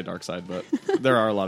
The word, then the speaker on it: Darkseid: So, like, he could, like, just send his entity into, the last intergalactic adventure has to do with Darkseid. – Darkseid? – Darkseid. – Darkseid. – Definitely spelled like seed. – It's still Darkseid.